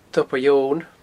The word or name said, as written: tobar eoghain